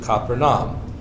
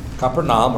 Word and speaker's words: Capernaum: After this, verse 12, after this, he went down to Capernaum with his mother, his brothers, and his disciples, and they remained there a few days. Capernaum, or Capernaum, however you want to pronounce it, is located on the north shore of the Sea of Galilee, kind of down in a bowl.